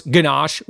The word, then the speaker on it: gnocchi: By the way, if you know how to pronounce ganache when you see it as G-N-O-C-C-H-I, well, again, you just got yourself another food prep gold star right there.